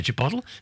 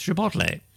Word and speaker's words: chipotle: I used to say chipotle. No, there's an air. It's chipotle.